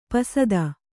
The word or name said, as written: ಪಸದ